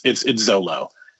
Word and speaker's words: Xolo: I just don't know how to say his first name. It's XOLO. – Oh, I would say Zolo. – It is. It's Zolo.